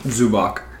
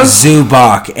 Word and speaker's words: Zubac: Zubac. – And I say Zubac because so, it's spelled Zubac. But Corbin says Zubats and thinks it's this.